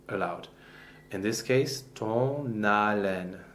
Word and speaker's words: haleine: So the word for uh, breath, haleine, the H is not pronounced. There is no sound that goes with an H in French. It's a silent consonant.